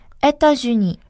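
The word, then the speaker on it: États-Unis: 에타주니. – 에타주니. – 그쵸. 그렇죠? 일 발음에 앞에 있는 에타에 있는 S가 붙어서 연음이 돼요.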